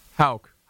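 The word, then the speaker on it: Hauch: Hawk, but everyone gets it wrong. – Hauk. How would you pronounce that, Toby? H-A-U-C-K. H-A-U-C-H, rather.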